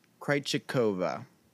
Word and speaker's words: Krejcikova: Her name's Barbara Krejcikova.